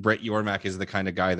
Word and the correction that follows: Yormark: It's like, you got mad about mispronouncing. It's Yormark. – Yormark. Got it.